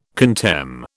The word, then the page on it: contemn: British English